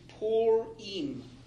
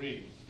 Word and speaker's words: Purim: I do want to correct one minor point. It is Purim. Not, Purim. not Purim. Okay. – Purim. – It's really easy though. It looks like the okay. word pure, and even my computer sometimes pronounces it that way, but it's Purim.